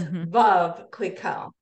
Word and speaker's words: Veuve Clicquot: So Veuve is pronounced like love. So it's Veuve Clicquot. We all kind of call it Veuve, but it's really Veuve Clicquot.